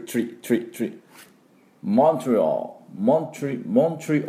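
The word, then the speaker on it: Montreal: ですからモントリオールというふうに発音していただきたいと思います。 M の音とトゥトゥトゥツバを飛ばす、でオールと U をつけないと最後にね、オールで、 AL ですからオールっていうふうに発音してください。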